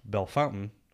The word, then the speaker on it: Bellefontaine: It is actually pronounced Bellefontaine, although it looks like Bellefontaine.